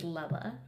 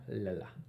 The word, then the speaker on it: Lella: If you could say her name right, I would appreciate it. It's Lella. – Lella. – Lella? – Lella. – No, say it with me. Lella.